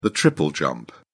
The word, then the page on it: triple jump: British English